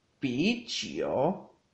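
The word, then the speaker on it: pichio (啤酒): Cerveza se dice pichio, pichio, pichio, con un segundo tono en la I de pi y un tercer tono en la, U de chio, pichio.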